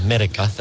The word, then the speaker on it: Medyka: Medica. That's how it's pronounced, M-E-D-Y-K-A.